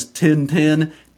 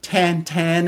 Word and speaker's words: Tintin: We would know him as Tintin, Tantan, as he would be pronounced by his creator, Hergé.